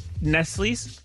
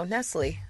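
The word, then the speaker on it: Nestlé: Nestle's? – No, Nestle. You don't pronounce the S.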